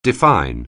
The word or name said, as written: define